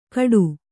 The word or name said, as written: ಕಡು